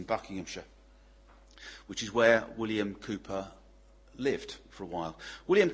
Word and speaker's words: Cowper: That portrait is of a man called William Cowper, or Cowper. It's actually pronounced, spelled C-O-W-P-E-R, but it's understood that that's how it's pronounced, Cowper, William Cowper.